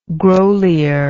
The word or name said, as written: Grolier